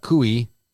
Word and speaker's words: Cooey: Katie Nicole Cooey, rhymes with gooey, 37, of Citra, was involved in a car accident about 2 a.m.